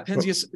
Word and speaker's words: Penzias: Was that Penzias? Is that how you say his name? – Yeah, Penzias and Wilson were the discoverers of that cosmic microwave background.